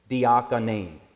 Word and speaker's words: diakonein: Diakonein is how you say it. It sounds a little bit like deacon, doesn't it?